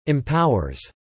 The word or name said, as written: empowers